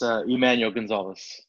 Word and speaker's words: Emanuel Gonzales: Yes, Emanuel Gonzales.